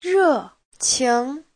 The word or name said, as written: re4qing2